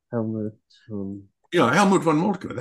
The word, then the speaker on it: Helmut von Moltke: Helmut von Moltke. That's right. – Yeah, that's right.